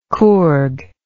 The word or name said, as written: coorg